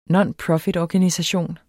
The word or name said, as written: nonprofitorganisation